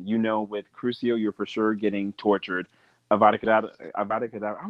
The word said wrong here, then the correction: Avada Kedavra: I keep saying it wrong. Avada Kedavra, you know for sure, like, okay, I'm dead.